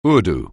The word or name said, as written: Urdu